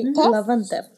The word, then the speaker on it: Love and Theft: Love and Theft. ¿Sí se dice así? – Sí, Love and Theft.